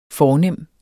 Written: fornem

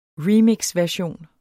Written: remixversion

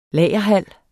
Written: lagerhal

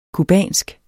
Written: cubansk

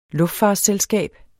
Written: luftfartsselskab

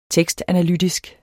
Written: tekstanalytisk

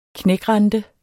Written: knækrente

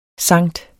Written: Sankt

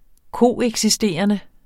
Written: koeksisterende

